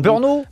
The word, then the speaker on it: Brno: Alors, j'ai toujours du mal à prononcer ce club, c'est Brno, je sais plus comment on dit exactement, vous avez une prononciation. – Brno, on dit, voilà. Brno.